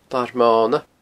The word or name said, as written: Larmona